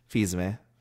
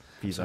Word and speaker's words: Fils-Aimé: Moving forward, Reggie Fils-Aimé. Is that how you say his name? – Yes. – Fils-Aimé.